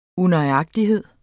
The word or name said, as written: unøjagtighed